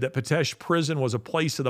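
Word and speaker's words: Pitești: And it looks like, if you want to look it up, it's spelled P-I-T-E-S-T-I, but it's pronounced Pitesti, but it looks like Pitesti.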